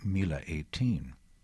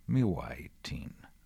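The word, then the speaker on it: Miła 18: best known of them being Mila 18, or to say it correctly, Miwa 18, by Leon Uris.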